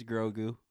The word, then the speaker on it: Grogu: It's Grogu.